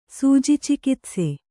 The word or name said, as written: ಸೂಜಿಚಿಕಿತ್ಸೆ